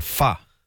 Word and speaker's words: pho: It's because I've always called it pho, but someone told me it's called pho. – Yeah, you're right. – Is it called pho? Yeah. – But I thought if I said that, no one would understand what I'm saying. – But it's spelt P-H-O.